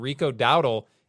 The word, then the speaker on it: Dowdle: And we're talking about Rico Dowdle, 8% owned in how do you say his name? – Rico Dowdle.